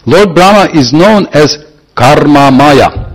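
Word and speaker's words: karma maya (मय): And this is Maya with short A. There is Maya, Maya. So that's the big Maya energy of Krishna, and with the short arm, karma maya, or in Bengali they will say karma moy, because they pronounce short a as o, karma moy.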